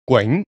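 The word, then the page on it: 囧: Yue; gwing2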